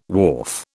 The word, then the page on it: wharf: British English